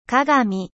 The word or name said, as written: mirroir